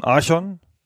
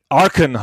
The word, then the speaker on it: Archon: Ich neige dazu, auf der ersten Silbe zu betonen. Archon, wenn schon.